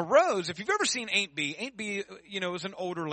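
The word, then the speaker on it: Aunt Bee: Well, not Aunt B. Ain't B. That's how they say it on the show.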